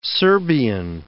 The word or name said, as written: Serbian